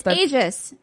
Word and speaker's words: Aegis: Okay, I say Aegis because that's how they say it in Xenoblade Chronicles 2. A-E-G-I-S.